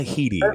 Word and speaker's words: Headey: Lena Headey is how you say her name.